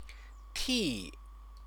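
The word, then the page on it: 替: tì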